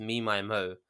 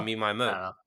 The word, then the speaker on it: MMM: She says on her like Bandcamp, it's pronounced Me, My, Mo.